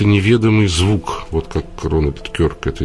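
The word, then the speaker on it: Kirk: Вот Рональд Кирк, которого, вы извините, Кёрк, он правильно произносится, который, я привык его Кирк произносить,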